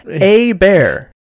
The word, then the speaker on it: Hebert: Pronounced A. – Bear.